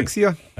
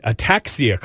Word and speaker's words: ataxia: Tagovailoa rules, he was not allowed to return because of the, I don't know how to say this word. Can you help me? – Ataxia? – Oh, thank you. Is that it? Ataxia clause.